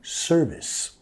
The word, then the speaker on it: service: Nota la acentuación de la palabra service. Vamos a enfatizar la primera sílaba, ¿ok? Service.